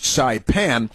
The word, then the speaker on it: Saipan: Now, the population of Saipan, which is pronounced Saipan, is 43,385, according to the U.S.